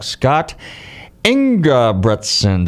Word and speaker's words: Scott Ingebretson: I'm delighted to welcome back Scott Ingebretson.